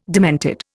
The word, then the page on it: demented: Indian